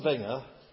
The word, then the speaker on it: Wenger: That's spelled with a W, not a V. W-E-N-G-E-R. Arsène Wenger.